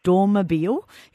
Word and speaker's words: Dormobile: I've got a 1974 V-Dub Combi Dormobile, if I'm pronouncing it correctly, camper. – Yes, you are.